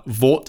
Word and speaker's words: VAWT: I've been doing a lot of reading on Vought technology and Hort technology, but how do I pronounce it? Is it acceptable for me to say Vought and Hort, or do I need to use it as the acronym and say H-A-W-T or V-A-W-T? – No, you pronounce it Vought or Hort.